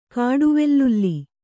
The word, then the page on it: ಕಾಡುವೆಲ್ಲುಲ್ಲಿ: kannada